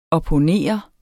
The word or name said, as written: opponere